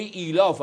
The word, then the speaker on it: ایلاف: و یعنی سوره بسیار روشن، سوره بسیار گویا، کلمه ایلاف هم که ایشون بلد نیست تلفظ کنه، کلمه ایلاف هست.